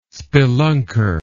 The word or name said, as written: spelunker